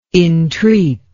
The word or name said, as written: intreat